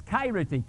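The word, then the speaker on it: kairete (χαίρετε): And the Greek word, the word that he used is kairete, K-A-I-R-E-T-E, with the accent on the first syllable, kairete.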